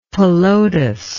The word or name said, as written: Pelotas